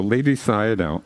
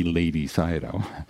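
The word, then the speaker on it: Ledi Sayadaw: So Lady Sayadaw, and that's spelled L E D I. It's not a Lady Sayadaw.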